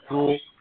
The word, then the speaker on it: Thule: It's spelled T-H-U-L-E, Thule.